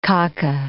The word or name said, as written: kaka